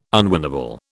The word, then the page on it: unwinnable: British English